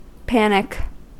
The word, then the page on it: panic: American English